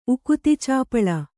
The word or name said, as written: ಉಕುತಿಚಾಪಳ